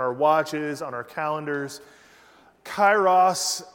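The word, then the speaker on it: Kairos (καιρός): And Kairos, if you're just joining us, now some people pronounce it, if you're English, right? If you speak English, you pronounce it Kairos, right? Chronos, Kairos. But it's actually a Greek word, and it is pronounced Kairos. Liz, am I right?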